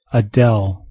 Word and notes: Adel: English

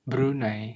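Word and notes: Brunei: Malay

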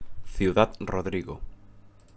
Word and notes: Ciudad Rodrigo: Spanish

